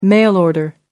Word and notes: mail order: American English

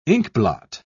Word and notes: inkblot: British English

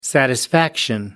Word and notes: satisfaction: American English

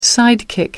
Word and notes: sidekick: American English

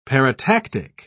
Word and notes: paratactic: British English

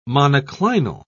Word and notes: monoclinal: British English

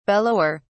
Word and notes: bellower: British English

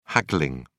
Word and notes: haggling: British English

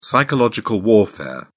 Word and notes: psychological warfare: British English

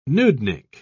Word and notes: nudnik: British English

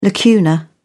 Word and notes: lacuna: British English

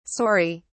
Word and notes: sori: British English

